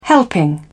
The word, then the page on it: helping: British English